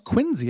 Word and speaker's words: Quincy: Those flooded cities like down in Quincy. Yeah. – Yeah. Yeah. Which is pronounced Quincy, apparently.